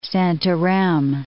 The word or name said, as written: Santarem